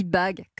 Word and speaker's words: BAG: Alors, ça se dit Bag comme un sac, hein. Mais ça s'écrit B.A.G parce qu'il y a une autre signification, c'est bonne action garantie.